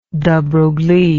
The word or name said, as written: de broglie